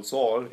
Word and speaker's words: Zahl: der heißt Paul Zahl, oder Paul Saul, wie er dann ausgesprochen wird.